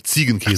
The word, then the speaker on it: Ziegenkäse: Zähnkäserollen. Ziegen, Ziegenkäse. Ach, Ziegen. Ja.